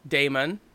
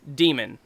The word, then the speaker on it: Daemon: I always said Daemon because it's got a fucking A in it. – Yeah. Right? – Daemon. – Uh-huh. – This is actually also a Latin term, and it's just pronounced demon.